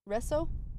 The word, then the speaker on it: réseau: It looks very French, so maybe you can say it. – Oh. Resso? – Yeah. Stemming from the French word réseau, meaning network.